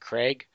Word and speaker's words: Krieg: I think it's spelled Krieg, but he pronounces it Craig, called Presidential Puppetry.